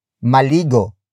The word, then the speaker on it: maligo: For example, maligo, maligo, maligo.